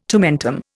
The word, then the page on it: tomentum: Indian